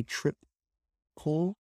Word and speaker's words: triple: And it was pronounced trip and then pull. Okay, trip, pull. The way it's spelled looks like tripel, right? Or trip, right? It's like a short L sound, but here we have a longer pull sound, right?